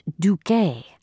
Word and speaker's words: Duque: "Ah, pronounced Duque, a Spanish duke.